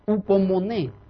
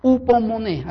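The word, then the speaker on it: upomone (ὑπομονή): Aquí la palabra paciencia en el original griego es upomone. Upomone, así nada más con H, pero así se escribe y doble E al final, upomone.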